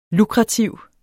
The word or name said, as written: lukrativ